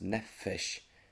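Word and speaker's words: nefesh (נפש): Og grunnteksten bruker et ord som heter nefesh, det uttales nefesh, det et hebraisk ord.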